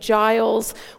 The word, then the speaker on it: Giles: Is there a place where all those things that catch in the back of the throat gather and shape themselves into something as soft as the G in Giles was meant to be pronounced?